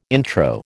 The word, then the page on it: intro: American English